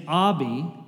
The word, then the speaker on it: Abby: It's like Bobby without the B. That I found that's the most effective way of explaining it. Again, my name is Abby, and it's okay if you get my name wrong.